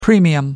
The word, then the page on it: premium: British English